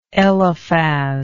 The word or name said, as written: eliphaz